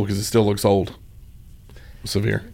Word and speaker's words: Sevier: So, Sevier Middle School. That's where I went to middle school. It is still called that. But it's not spelled like severe weather. It's spelled like Sevierville, Tennessee.